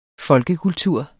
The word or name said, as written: folkekultur